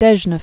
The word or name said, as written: Cape Dezhnev